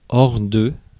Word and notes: out: /ˈaʊt/

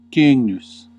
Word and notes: cygnus: Classical; /ˈkʏŋ.nus/

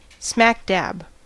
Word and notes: прямо: /ˈprʲamə/

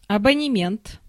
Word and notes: абонемент: [a.bɒ.nɛ.'mʲɛnt]; /əbənʲɪˈmʲent/

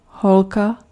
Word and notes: girlfriend: American English; [ˈɡɝl.ˌfɹɛnd]

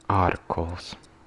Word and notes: plough: /plaʊ/; [plaʊ]

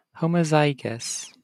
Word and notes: homozygous: American English

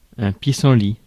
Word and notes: pissenlit: France; [ɛ̃ pi.sɑ̃.li]

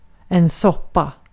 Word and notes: soppa: /ˈsɔpˌa/; /ˈsɔˌpa/

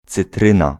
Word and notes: citroen: /siˈtrun/

